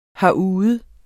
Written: herude

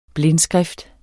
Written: blindskrift

